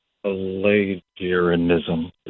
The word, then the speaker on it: Pelagianism: But then last week you brought up Pelagianism. Is that right? I think I said it right. – Pelagianism, I think.